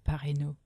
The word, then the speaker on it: Parreno: Philip Perino, Philippe Parreno.